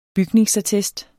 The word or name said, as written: BYGNINGSATTEST